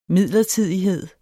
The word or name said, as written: midlertidighed